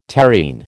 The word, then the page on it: terrene: American English